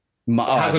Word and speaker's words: Maar: Is it Maar or Maar? – Maar. It's definitely two syllables.